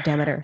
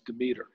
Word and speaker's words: Demeter: Or Demeter, sorry. – There are several that I've tried. By the way, you know, the original pronunciation was Demeter. It's based on the Greek goddess of agriculture.